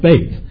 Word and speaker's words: Beit: It's pronounced bait. It's in English sounds like Beth, but it's bait.